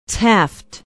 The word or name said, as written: taft